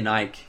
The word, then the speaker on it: Nike: Do you guys say Nike or Nike? – We say Nike, yeah, sure, probably should have said that. We don't say Nike, we say Nike.